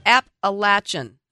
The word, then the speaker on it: Appalachian: For those of you listening, that's pronounced Appalachian. And not the other way, as most of you probably say it.